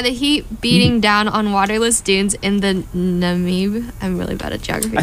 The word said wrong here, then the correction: Namib: I think you mispronounced that. – Namib. No. – One more time for luck. – It's like a... Yeah, there's an N-A-M-I-B in there.